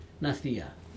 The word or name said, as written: nadia